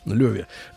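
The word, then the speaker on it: Loewe: торговая марка, пишется Лоеве через W, Леве.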